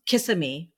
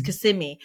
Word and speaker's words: Kissimmee: I used to say Kissimmee forever outside of Orlando, and it's Kissimmee. I mean, same thing.